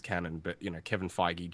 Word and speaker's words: Feige: No, Kevin Feige.